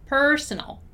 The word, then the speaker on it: personal: While personal, the stressed syllable of personal is per, okay? So personnel and personal, personnel and personal, personnel, personal.